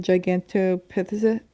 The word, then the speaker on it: Gigantopithecus: So the Yeti is described as bipedal, and most scientists believe that the Gigantopithecus, is that how you say it? – Mm-hmm. You got it.